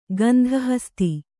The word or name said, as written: ಗಂಧಹಸ್ತಿ